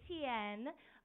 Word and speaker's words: Tien: My name is Tien, but my full name is actually Han Tien, which is spelled H-A-N-H-T-I-E-N.